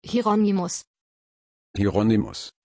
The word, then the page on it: hieronymus: German